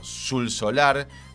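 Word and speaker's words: Xul Solar: Hay una casa donde vivió Sul Solar, que se escribe con X, le pueden pedir un grande, que es la casa donde él vivió transformada hoy en una especie de...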